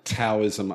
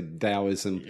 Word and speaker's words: Taoism: So it's pronounced with a D, is it? Like, some people say Taoism, others say Taoism. – Right. – And I don't know whether it's to do with the sound of the Chinese language and how they say it, but I think they tend to say Tao rather than Tao with a hard T.